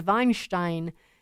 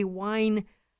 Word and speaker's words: Weinstein: I keep saying Harvey's name wrong because the German pronunciation is Weinstein, but he doesn't say it that way. It's Harvey Weinstein.